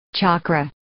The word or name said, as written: chakra